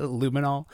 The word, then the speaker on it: luminol: Yeah, there were Illuminati tests done in the woods. Oh, is that how you say it? Luminol? Okay. There were luminol tests done in the woods, too.